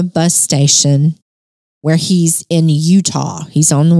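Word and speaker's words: Eutaw: We don't spell it the right way. It's E-U-T-A-W. – Yep. Utah. – But it's Utah.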